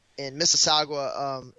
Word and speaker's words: Mississauga: Well, they also had that D-League showcase in Mississauga. Did I say that right? In Mississauga, Ontario? – Yep.